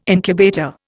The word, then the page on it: incubator: Indian